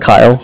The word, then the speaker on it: Kyle: Kyle. K-Y-L-E.